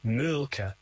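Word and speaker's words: Mölkky: We use Mulkey. Most of the people we play against use Mulkey, but the Finns have a very unusual pronunciation, which, please forgive me, I'll try it. It's Mulka. So I think that's, something a bit more like that. But to us, it's Mulkey.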